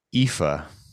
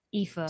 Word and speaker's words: Aoife: What's AOIFE? How do you say that? – Irish. – No thoughts. – Oif. – It looks like Aouif. – Aouif. – Ife or something. – Oh, no. – Should I just like look this up? – Yeah, probably. – Yeah. AOIFE. – Before we say pronunciation. It says IFA.